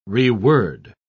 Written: reword

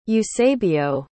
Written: eusebio

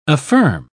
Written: affirm

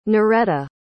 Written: Noreta

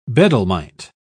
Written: bedlamite

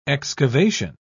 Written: excavation

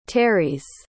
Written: terese

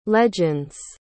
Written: legends

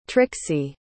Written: trixie

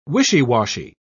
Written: wishy-washy

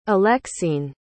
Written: alexine

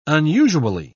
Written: unusually

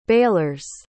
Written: balers

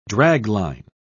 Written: dragline

